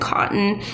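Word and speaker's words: Kotn: The second one is, I might not be pronouncing it right, but I think it's pronounced cotton. It's K-O-T-N.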